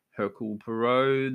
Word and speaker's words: Hercule Poirot: It's a tough name to pronounce, but I'm pretty sure it's Hercule Poirot.